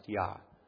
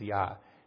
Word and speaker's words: Yah: But why would the first syllable be Yah? There's no question that the first syllable was pronounced Yah. Just don't know if there was two or three syllables and what those second or third syllables would have been. But the first is Yah.